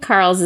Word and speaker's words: Carles: From 2016, directed by Carles Torrens. Is that how you say his name? Carles? – I think Carles is good.